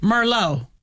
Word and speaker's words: Merlot: Yeah, remember Merlot is, and that T is silent the T on is the silent.